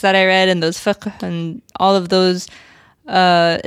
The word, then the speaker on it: fiqh: But you pronouncing fiqh like it's it's hundred percent. Your Arabic is on point.